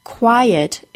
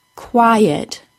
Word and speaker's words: quiet: First, and most importantly, quiet is a two-syllable word, stressed on the first syllable, quiet. Secondly, the word quiet is pronounced with two vowel sounds next to each other. I hope you remember from last week that we need to add a Y sound or a W sound between the vowel sounds to make each sound nice and clear. Quiet gets a tiny Y sound added.